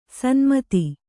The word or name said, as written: ಷಣ್ಮತ